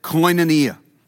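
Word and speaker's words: koinonia (κοινωνία): Here's how you say it, koinonia.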